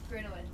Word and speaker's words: Grinowitz: Grinowitz. – Grinowitz. – Grinowitz. Grin. It's like grin, like you're smiling.